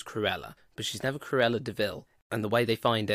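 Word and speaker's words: de Vil: It's like, it's spelt devil, but pronounced DeVille.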